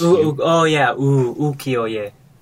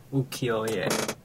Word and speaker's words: ukiyo-e: I think it's ukiyo, right? Because it's... – Ukiyo-e. Ukiyo-e. Yeah.